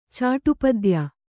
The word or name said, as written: ಚಾಟುಪದ್ಯ